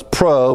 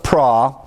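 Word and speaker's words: pra (πρό): You say, well, it's pro, but in the Greek, pronounce it pra.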